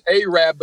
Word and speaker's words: Arab: No, it is Arab because the original name of the town was Arad, but they misspelled it on the water tower. So now it's Arab.